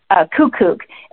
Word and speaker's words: QUQUQ: It's a company called Q-U-Q-U-Q, and you pronounce it Kukuk.